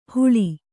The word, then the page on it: ಹುಳಿ: huḷi